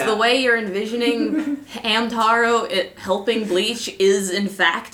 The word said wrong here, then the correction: Hanataro: Oh, Hanataro. That's how they pronounce it in the show.